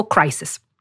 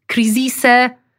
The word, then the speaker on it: crisis: Crisis, crisis, crisise. Serio. Nie. – No jest ponad 20 języków, wiesz, ja wiem, w całej Europie. No. – Crisis to jedyna poprawna wymowa.